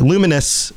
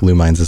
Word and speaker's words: Lumines: But Luminous is how you pronounce it. I still think Lumines is good.